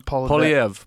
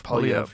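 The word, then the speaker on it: Poilievre: Poliev. – Poliev.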